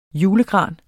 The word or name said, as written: julegran